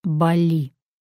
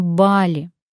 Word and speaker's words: Бали: Мои друзья каждый год ездят на Бали. И зря, потому что ездить надо на Бали. Такое ударение отмечено в словарях.